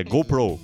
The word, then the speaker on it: GoPro: Aqui a gente pronuncia GoPro o tempo todo, GoPro. É um modo brasileirado, mas o nome certo em inglês, né? A pronúncia correta é GoPro. GoPro, eles falam GoPro Cameras.